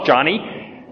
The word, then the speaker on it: Joni: People think her name is Joni, J-O-N-I. It's actually pronounced Johnny.